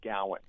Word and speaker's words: Gowan: Yeah, no, it's hard to tell from the spelling. It's Gowan. – Gowan. – So it rhymes with like Chowan.